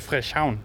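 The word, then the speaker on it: Frederikshavn: Ja, øh, og så Frischhavn, som det nok udtales øh, lokalt.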